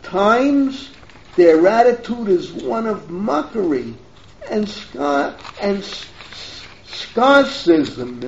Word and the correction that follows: sarcasm: Sarcasm, that's the word I want.